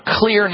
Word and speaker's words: Xlear: We get off all dairy foods and chocolate, and we start using clear nasal wash or clear nasal spray. It's spelled X-L-E-A-R.